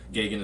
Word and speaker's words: Geoghegan: Gagan is spelled GEOGHEGAN.